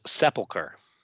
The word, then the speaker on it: sepulcher: the emphasis is on the first syllable, so it's sepulcher.